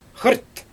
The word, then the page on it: xrt: Xyzyl